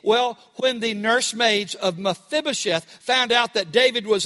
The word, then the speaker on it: Mephibosheth: You have to put the emphasis on the F, Mephibosheth.